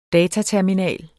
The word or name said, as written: dataterminal